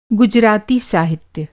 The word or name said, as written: गुजराती